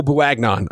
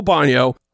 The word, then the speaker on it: Bouagnon: I think Mays is in, and I think Joel Bonio, and I'm going to call him Joel Bouagnon, and I know that's not how you pronounce it, but that's I've been reading about him, and it was like, well, it's Bouagnon. I mean, it looks like Bouagnon, but it's not. It's Joel Bonio.